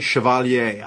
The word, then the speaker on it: Chevalier: And I pronounce it the French way, Chevalier. I don't know if it's Chevalier, like the way, you know, but think about Chevrolet. That's the way I'm going with it.